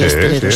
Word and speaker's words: esteleros: Dice Inma, en Canarias a los componedores de huesos se les llama esteleros. ¿Lo he dicho bien? Sí, sí, esteleros. Sí, sí. Estelero, sí.